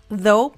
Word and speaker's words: though: though, porque creo que muchas personas no saben cómo se escribe exactamente y solo utilizan T-H-O, though, porque las otras realmente no suenan.